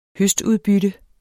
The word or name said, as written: høstudbytte